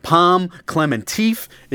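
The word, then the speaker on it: Pom Klementieff: Pom Clementeef is Mantis.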